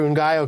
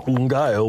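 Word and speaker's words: Ngaio: I was going to say there might not be any questions for Ungayo because everyone's going crazy trying to figure out how to spell it. It's N-G-A-I-O. – Ungayo was his name, oh.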